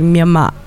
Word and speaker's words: Myanmar: And what a lot of European language speakers don't hear is that the Burmese pronunciation of the country is actually Myanmar. And Myanmar and Burma sound much more similar than Myanmar, Myanmar, and Burma, right?